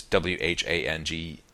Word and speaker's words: Whangarei: Now, normally that's pronounced F, as in Frank. For example, there's a town in Northland, north of Auckland, called Whangarei, which is Whangarei. And that's how, in this part of the country, we pronounce it, Whangarei.